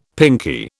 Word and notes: pinkie: British English

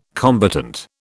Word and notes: combatant: British English

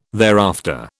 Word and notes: thereafter: British English

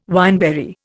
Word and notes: wineberry: Indian

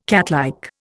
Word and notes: catlike: Indian

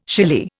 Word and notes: shily: Indian

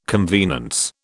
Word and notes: convenance: British English

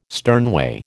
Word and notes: sternway: American English

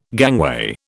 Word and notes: gangway: British English

